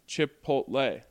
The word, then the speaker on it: Chipotle: Chipotle is pronounced Chipotle. That is correct. – Chipotle.